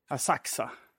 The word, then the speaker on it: Asakusa: Som man säger, för jag har lite svårt att fatta när man ska uttala U... eller inte. Alltså för vi bor nu i Asakusa. – Mm. – Men det stavas Asakusa.